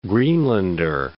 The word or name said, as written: Greenlander